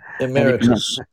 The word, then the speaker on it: emeritus: and one guy's like a professor emeritus, is that how you pronounce it? – Emeritus. – Emeritus, is it Irish? – Emeritus. Professor Emeritus.